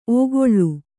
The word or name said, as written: ಓಕೊಳ್ಳು